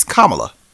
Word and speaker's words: Kamala: I was like, it's got to be Kamala. It can't be Kamala because it sounds like comma with la at the end.